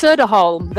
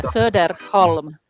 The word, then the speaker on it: Soderholm: It's Soderholm. There's no L in it. Yeah, Soderholm. Maija Soderholm, if you want to get like super, super specific.